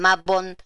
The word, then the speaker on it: Mabon: el Maybon, Mabon. Si lo buscan por internet, buscan Mabon, porque se escribe así, Mabon. M-A, B larga, O-N, ¿sí? Bueno,